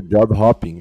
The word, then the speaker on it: job hopping: Tu falou assim, job hoping, né? E a pronúncia correta seria job hopping, eu acho, né? Porque hopping é pular, né? Hopping é pular e hoping é esperança.